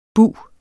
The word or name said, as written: bug